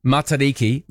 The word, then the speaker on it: Matariki: I wouldn't say it's going to become my Matariki tradition to go and see a live gig, but why not? Hi, Roman. In terms of the pronunciation, Matariki, mata like butter, it's the same sound.